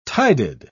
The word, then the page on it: tided: British English